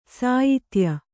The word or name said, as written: ಸಾಯಿತ್ಯ